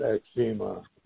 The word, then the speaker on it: eczema: And the only other thing going on with me is I continue to be plagued by this horrible skin condition called eczema, or some people pronounce it eczema.